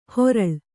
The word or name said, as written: ಹೊರಳ್